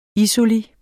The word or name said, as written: isoli